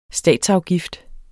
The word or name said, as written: statsafgift